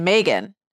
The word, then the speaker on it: Meagan: So another kind of funny thing about my name is that my mom always pronounced my name Meagan. Uh-huh. And that's what my name was supposed to be. And almost nobody pronounces my name Meagan, to the point now that I've almost given up.